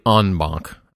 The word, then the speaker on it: en banc: We talked a little bit already, just you and I, about how likely it is that maybe the Sixth Circuit would reconsider in bank or in banc, as Paul said. That was one other thing that listener Paul commented on, Joe, was pronunciation there. Yeah. – Yes. – And really en banc, I guess. – Yeah. – Right. Or as you say, en banc.